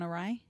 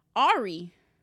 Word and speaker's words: awry: And some people pronounce it Ari.